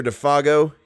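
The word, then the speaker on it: Défago: Good luck, Monsieur Defago, he added facetiously, giving the name its French pronunciation for once.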